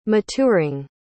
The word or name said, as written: maturing